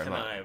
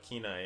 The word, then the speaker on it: Kenai: Not sorry, Kenai, but Kenai, yeah.